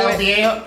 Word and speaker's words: El Viejo: How do I pronounce Viejo? – That? – What? Is it? – Yeah. – How do El Viejo. – El Viejo. – Yes, El Viejo.